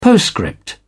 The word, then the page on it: postscript: British English